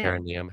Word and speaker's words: perineum: Perineum. How do you pronounce that? Pronounce it. Perin- perineum. Yeah, yeah.